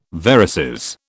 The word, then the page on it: varices: British English